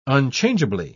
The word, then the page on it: unchangeably: British English